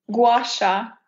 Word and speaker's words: gua sha: And I can never, ever pronounce this correctly. The gua shia? Gua shia? How do you say that? – Gua sha.